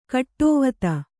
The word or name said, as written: ಗಾಡಿವತಿ